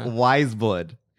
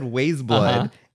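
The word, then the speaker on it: Weyes Blood: So I first and it's Wise Blood. Because for the longest time I said Waze Blood and everybody corrected it on YouTube because it's like, how do you pronounce her actual name? – Okay.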